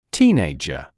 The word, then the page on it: teenager: ‘tiːneɪʤə; ‘тиːнэйджэ